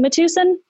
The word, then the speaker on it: Matuson: Looks like Matuson, sounds like Madison.